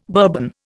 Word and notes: Bourbon: Indian

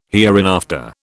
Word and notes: hereinafter: British English

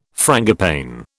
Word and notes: frangipane: British English

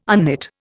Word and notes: unknit: Indian

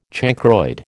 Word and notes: chancroid: American English

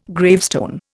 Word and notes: gravestone: Indian

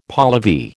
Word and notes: Pahlavi: American English

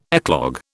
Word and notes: eclogue: British English

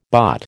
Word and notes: bot: American English

